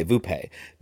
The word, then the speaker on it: Vuppe: I wrote the subtitle, which is V-U-P-P-E, and then I wrote how you pronounce it, which is V-O-O-P-A-Y, Vupe. That's what he says.